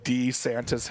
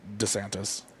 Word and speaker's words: DeSantis: I have a friend of mine who has the same last name as him, and he pronounces it DeSantis. – I guess DeSantis.